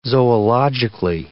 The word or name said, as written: zoologically